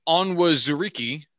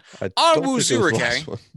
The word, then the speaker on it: Onwuzurike: They took uh, offensive tackle Penei Sewell, defensive tackle Levi Onwuzuriki. Onwuzuriki. – Can't help you there. – Onwuzurike. I'm not sure how the pronunciation there at the end. – Onwuzurike.